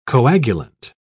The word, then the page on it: coagulant: British English